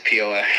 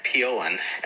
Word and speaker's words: Peoa: I mean, we said Peoa, but we're hoping we say that right. – Oh, it is Peoa.